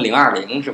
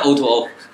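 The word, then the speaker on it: O2O: 好多人给读成零二零是吧？对，O to O，啥叫 O to O？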